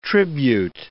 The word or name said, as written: tribute